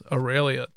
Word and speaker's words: Aurelia: My next one would be Aurelia. Is that her name? Is that how you say her name? – Yes, Aurelia.